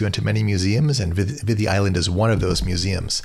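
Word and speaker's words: Viðey: This is often spelled Vidi Island, V-I-D-E-Y, but it has one of those thorn D's in the middle, so it's pronounced as the Vidi Island.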